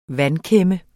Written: vandkæmme

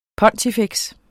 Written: pontifex

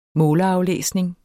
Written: foder

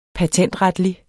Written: patentretlig

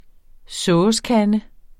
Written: SOVSEKANDE

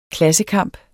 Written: klassekamp

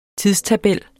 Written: TIDSTABELLER

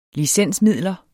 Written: licensmidler

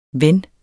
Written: ven

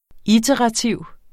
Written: iterativ

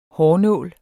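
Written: HÅRNÅLE

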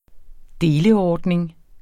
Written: deleordning

